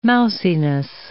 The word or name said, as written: mousiness